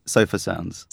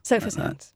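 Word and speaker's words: Sofar Sounds: How do you pronounce the name of your group? – That's a good question. Nobody knows. So it's Sofa Sounds. – Sofa Sounds.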